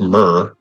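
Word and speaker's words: mere: So in British English, Mir, pronounced Myr, is a lake, pond, or air or arm of the sea.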